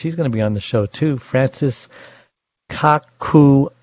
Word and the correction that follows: Kakugawa: Did I say that wrong? – Kakugawa. – Kakugawa.